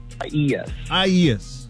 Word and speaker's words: Isaias: Is it Isaias? Did I say that right? – Isaias.